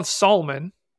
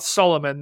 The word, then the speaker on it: Sahlman: Yeah, Colin Solomon, not Solomon. There's not an extra syllable in there, but I was impressed.